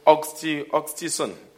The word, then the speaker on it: oxytocin: There's a hormone that they call oxytocin. Am I pronouncing it right? – Yeah.